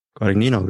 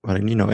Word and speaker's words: Guadagnino: Can you pronounce the last name? You sounded really good pronouncing his last name. Guadagnino, very good. Guadagnino.